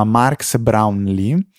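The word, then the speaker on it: Marques Brownlee: E um, ultima segnalazione che vale la pena fare assolutamente è quella di un video che ha registrato Mark Brownlee, non riuscirò mai a pronunciarlo bene, si chiama Marx Brownlee con Craig Federighi.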